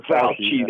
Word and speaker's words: Fauci: Fauci. – Dr. Fauci.